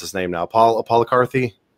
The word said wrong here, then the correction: Polycarp: He was an understudy of Polycarp. – A Polycarp, that's what I meant. Yeah, Polycarp. He was an understudy of,